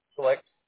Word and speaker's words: Schlicht: Schlicht. – How do I spell that, please? – S-C-H-L-I-C-H-T. – So S-C-H-L-I-C-H-T? – Yep.